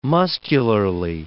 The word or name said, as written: muscularly